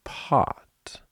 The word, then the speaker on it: pot: Pot is spelled with an O, but it has an ah sound. Try it yourself. Pot. It's ah. Spelling.